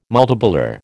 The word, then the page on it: multipolar: American English